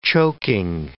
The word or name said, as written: choking